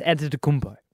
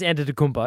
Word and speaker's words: Antetokounmpo: It's pronounced Antetokounmpo. – Antetokounmpo. – Yeah, you're close. Giannis Antetokounmpo.